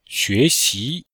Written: xue2xi2

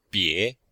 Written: bie2

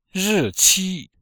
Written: ri4qi1

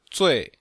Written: zui4

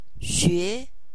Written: nv-xue2

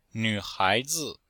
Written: nv3hai2zi5

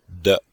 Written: de5

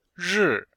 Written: ri4

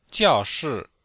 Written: jiao4shi4

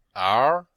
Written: er2

